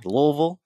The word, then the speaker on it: Louisville: I think I said that right, didn't I? Louisville? – Yeah, you did.